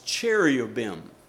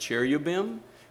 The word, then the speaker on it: cherubim: And then on the ends of this 4 foot, on the end of the lid, there were two sculptures of two angels, cherubim. And you can say that cherubim, cherubim. We'll say cherubim. There are several ways to pronounce it.